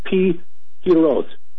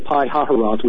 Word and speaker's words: Pi-Hahiroth: it's Pi-Hiroth, looks like Pi-Hahiroth, which is the way I've been saying it, but I guess in Hebrew it's a Pi-Hiroth, in front of a place called Baal Zephon, and that's what caught my attention.